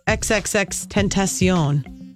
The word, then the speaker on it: XXXTentacion: Boy, this shooting death of this rapper, XXXTentacion. That's how you pronounce his name.